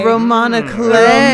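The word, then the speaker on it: roman à clef: Well, apparently it's pronounced like Roman Aclay.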